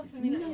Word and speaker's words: Smino: Oh, isn't it like Smino? – Oh, Smino, yes.